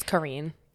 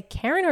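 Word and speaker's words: Carine: It's Karine because she's, you know, French.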